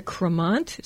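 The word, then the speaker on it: Crémant: B-I-C-H-O-T. You could pronounce it to remember it. Bichot. B-I-C-H-O-T. Bichot. And it's a Cremant, C-R-E-M-A-N-T. Think of creamy. Rosé. R-O-S-E.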